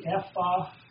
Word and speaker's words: ephah: You could say the ephah basket. The basket that holds this much. Apparently, this was the ephah. Am I saying that right? Ephah? E-P-H-A-H.